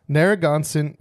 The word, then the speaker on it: Narragansett: Now, did I say it right? – Narragansett. – Narragansett.